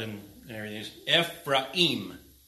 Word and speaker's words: Ephraim: Now, I read verses 1 and 3 because all of you pronounced that word wrong. But Ephraim. Say it with me.